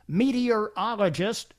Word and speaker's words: meteorologist: I'm 64, and thanks to your show, I finally learned how to pronounce meteorologist correctly when you said to picture a meaty urologist.